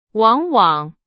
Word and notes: 往往: Chinese

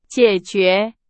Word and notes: 解决: Chinese

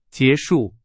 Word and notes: 结束: Chinese

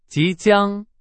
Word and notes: 即将: Chinese

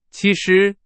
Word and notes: 其实: Chinese

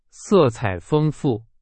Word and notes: 色彩: Chinese

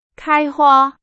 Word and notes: 开花: Chinese

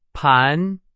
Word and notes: 盘: Chinese

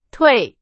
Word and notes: 退: Chinese

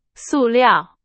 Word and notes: 塑料: Chinese

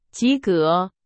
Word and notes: 及格: Chinese

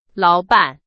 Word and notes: 老板: Chinese

